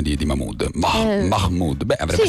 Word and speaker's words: Mahmood: Ma tu lo dici Mahmood o Mahmood? Perché si deve pronunciare, sì, davvero? – Sì, ha detto anche lui, lo vuole pronunciare un po' più all'araba, diciamo.